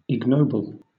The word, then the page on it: ignoble: British English